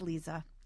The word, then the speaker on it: Liza: It's Liza. I'm just going to say that so you don't feel like, I always hate it when I mispronounce and someone tells me like 10 minutes later that they've mispronounced a name. So it's Liza.